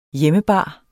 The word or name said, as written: hjemmebar